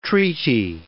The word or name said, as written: treaty